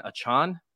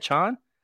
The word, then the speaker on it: Achane: Is, is that how you say it, Achane? – I thought it rhymed with train.